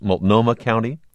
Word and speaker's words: Multnomah: They're in Portland or in the surrounding, is it pronounced Multnomah County? – Uh-huh, exactly right.